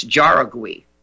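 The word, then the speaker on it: Jauregui: J-A-U-R-E-G-U-I. I'll just pronounce it the way it looks, Jaragui. Philip L.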